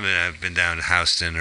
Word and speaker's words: Houston: I was walking down Houston. – And it's called pronounced Houston? – No, it's Houston.